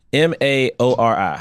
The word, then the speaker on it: Maori: He is Maori, M A O R I.